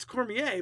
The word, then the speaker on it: Cormier: No, it's Cormier, but I try to say it wrong because that's who I am.